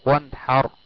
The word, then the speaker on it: وانحر: رابعا، إذا جاء بعد النون الساكنة حرف الحاء وهو من حروف الإظهار الحلقي ككلمة فصلي لربك وانحر، وانحر، وانحر، النون جاء بعدها حرف الحاء وهو من حروف الإظهار الحلقي فأظهرت وتنطق وانحر.